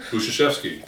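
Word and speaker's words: Budziszewski: And I went to a summer conference last summer and listened to a professor named Jay Budzewski. – Buzuszewski. – Thank you for correctly pronouncing his name.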